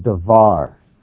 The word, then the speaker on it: davar: And the word there for matter is the word davar. You could spell that D-A-V-A-R, davar.